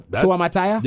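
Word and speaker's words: Suamataia: Do you know how to pronounce this? Suamataya. – That. – Suamataya. – Yeah. – Kingsley Suamataya from BYU. – That sounds about right.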